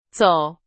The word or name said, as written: 走